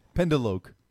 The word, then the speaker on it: pendeloque: Pendelwick. – I want the origin, and I want you to say it again because I guarantee that's not how it's pronounced. – Okay, the word is pendeloque.